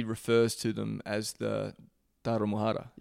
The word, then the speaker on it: Tarahumara: It's very interesting because Rarámuris live in the Tarahumara mountain range. The mountain range is called Tarahumara, but their ethnicity is Rarámuri. So it's just a mis... how do I say this? It's just... you don't say Tarahumara as a person. Like, he's not a Tarahumara, he's a Rarámuri.